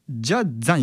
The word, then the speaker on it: just the: まずこの Just の最後の T の発音ですね、この子音の T が消えてるんですよ。なので Just the は Ja-za に聞こえてしまうんですよね。